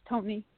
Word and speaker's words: Toni: My name is Tony. – How do you spell that? Sorry. – T-O-N-I.